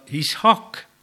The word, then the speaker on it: Ishak: ja Ishak, kui nii õigemini hääldada seda, sinna tuli samamoodi see H-täht sisse.